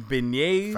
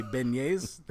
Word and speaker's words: beignets: You know, they have the Eiffel Tower and beignets. – French fries. – That's how you say it. You say beignets.